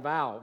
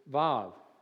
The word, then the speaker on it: Vav: And if you look at it just from your English perspective, at least in my Bible, it looks like vow, V-A-U. At least that's how I'd pronounce it. But it's actually pronounced, if you spoke Hebrew, Vav, Vav. It's got a soft ah sound there, and what looks like a U in my Bible is actually a V, a pronunciation. So it's Vav.